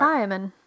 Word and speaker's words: thiamin: Thiamin.